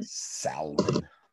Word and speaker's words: salmon: Gonna make this salmon and then go get my nails done. – Salmon. People get really, people get really disturbed by folks. – People pronouncing the L.